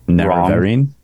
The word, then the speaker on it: Nerevarine: In which game of the series is the player character referred to as the Nerevarine, or however you want to pronounce that? How do you say it? – Nerevarine.